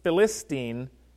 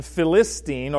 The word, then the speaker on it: Philistine: But original Hebrew didn't have vowels, and so the consonants in Philistine are the same consonants in Palestine, except we pronounce the P like a P instead of a F. But the Hebrew letter Pe can be pronounced P or F, depending on what word it's used in. It can be either a hard P sound or it can be a PH sound.